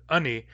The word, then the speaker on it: ani: And in addition, the important glue word that we're talking about here, ani, is only pronounced ani in Barkly Sound dialects. In Central Northern dialects, it's pronounced in, glottal stop I-N, in.